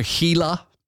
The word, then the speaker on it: Gila: I think it's called Gila, which is spelled Gila like a Gila monster. Gila.